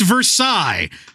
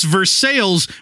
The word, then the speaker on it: Versailles: I do, because it's pronounced Versailles. That's where they signed the freaking treaty, man. But in Indiana, it's Versailles, which is just north of Vevey, Indiana, which is spelled V-E-V-A-Y.